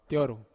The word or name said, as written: tioru